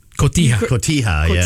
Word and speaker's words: Cotija: I just told you the male version of the cheese, cojito. – Cotija, that's actually where we're going.